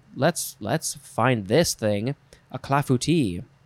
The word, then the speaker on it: clafouti: Next is clafouti. No, that's not how you pronounce it. You say, I think that's the, I emphasize the wrong parts. Clafouti, clafouti. I believe that's correct. It is spelled C-L-A-F-O-U-T-I, or you can put an S at the end.